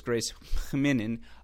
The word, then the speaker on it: Minnen: Grace Hinnin knocks off Diana Yastremska 6-4, 6-3, and I know the pronunciation, you know, it's spelled M-I-N-N-E-N. Go listen to the WTA website. It's more of an H than an M, so it's Grace Hinnin.